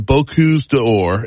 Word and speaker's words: Bocuse d'Or: The Bocuse d'Or and the Pastry World Cup coming up.